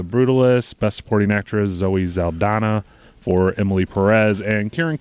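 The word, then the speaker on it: Saldaña: Because when they pronounced it last night, I saw in the clip I saw this morning, it was Saldana is how they pronounced her last name.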